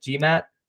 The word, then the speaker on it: GMAT: Is it your GMAT? Is that how you pronounce it? GMAT? – Yeah. Yeah.